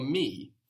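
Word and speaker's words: Me (με): The word for me in Greek is the same as in English. You just pronounce it more like how it's written. Me, me.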